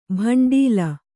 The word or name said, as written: ಭಂಡೀಲ